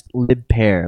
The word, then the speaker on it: Lib Pear: Lib Pear is the next entry, pronounced Lib Pear, like Lib Par.